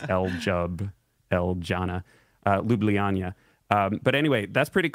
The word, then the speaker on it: Ljubljana: Ljubljana, which I had to learn how to pronounce because it's spelled L-J-U-B-L-J-A-N-A.